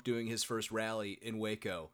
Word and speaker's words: Waco: By the way, I know it's not necessarily the topic of the video, but have you seen there's all this Wacko stuff? – What stuff? – Waco.